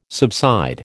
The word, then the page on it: subside: American English